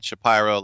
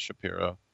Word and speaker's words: Shapiro: Anthopoulos was running the show, and then Shapiro left Cleveland, and that's how he does pronounce it, by the way, not Shapiro.